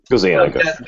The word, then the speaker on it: Gazzaniga: uh, I can never say his name right. I'm sorry. – Gazzaniga. Gazzaniga is the Italian pronunciation.